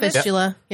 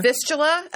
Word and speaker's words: fistula: But it left him with, and I believe I'm pronouncing this correctly, a fistula, which is a hole. – A fistula. Yeah. – Fistula.